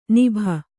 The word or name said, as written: ನಭಿ